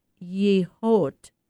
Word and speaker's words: Yehaw: The show uh, spelled Y E H A W and pronounced Yehot, includes 200 works showcasing contemporary Native American art, Native American Indian art.